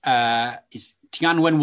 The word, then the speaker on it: Tianwen: So this new mission is Tianwen 1. Is that appropriate pronunciation? – Yeah, that's not bad.